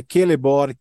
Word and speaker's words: Celeborn: Tá, é, isso, o início do, da letra C, o Tolkien, ele colocou a letra C como, como se fosse a letra K, ou como se fosse o Q e E. – Então é Celeborn, Kirdan, é Kelegorn, enfim, tem todo, toda, toda. – É só um é, ajuste de pronúncia.